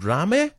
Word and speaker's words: Rame: Rame? – That's correct, yeah, you got it.